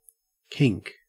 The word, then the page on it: kink: Australian English